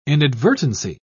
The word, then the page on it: inadvertency: British English